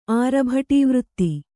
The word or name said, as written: ಆರಭಟೀವೃತ್ತಿ